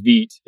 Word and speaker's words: Vite: And then the last thing I want to mention is Vite. Yes, it's spelled V-I-T-E, but that's French for fast along those lines, Mr. Baker.